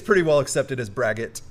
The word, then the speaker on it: braggot: So it is etymologically, it is a Welsh word, and I'll still butcher this, but it would originally have been something similar to Bragaud. But Braggot? Just Braggot is pretty good.